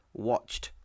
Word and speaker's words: watched: To watch, watched. It has like a T sound at the end of it. You've got to play, played. But it's the same way it's spelled, ED, but played is a D sound, watched is a T sound.